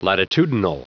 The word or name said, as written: latitudinal